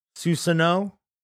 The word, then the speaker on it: Susanoo: How do you say this word? Is it Susanoo form? – The giant. – the energy? Susanoo. – Susanoo. The, the Susanoo. Susanoo. Okay, thank you.